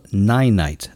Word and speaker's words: Ninite: It's called Ninite. That's spelled N-I-N-I-T-E.